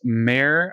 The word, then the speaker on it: Mare: Another one that's recently reviewed by us here at Upload VR is Mare, I think is how you pronounce it. M-A-R-E, Mare. It could be Mara, but I think it's Mare.